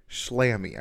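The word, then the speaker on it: Schlamme: So, the man who directed episode one and two, who is an executive producer of the show, his name, I, I believe it's pronounced Slammy.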